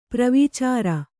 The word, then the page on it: ಪ್ರವೀಚಾರ: Kannada